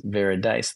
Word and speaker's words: Veridise: It's so funny you're saying that because internally, when we were talking about this upcoming space, I was mispronouncing your name. I was like Veridis or just mispronouncing it. And so Shalini was always correcting me. And so literally I was like, oh, like very nice, Veridice. That's actually how I was remembering how to pronounce your name.